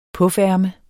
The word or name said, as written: PUF